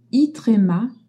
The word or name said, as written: i accent trema